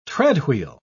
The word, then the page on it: treadwheel: British English